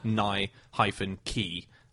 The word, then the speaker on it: Nike: All he did was just circle and send it back to them, and he circled Nye-key. As in a key opening a door. – Yeah. – So it's right. Nike.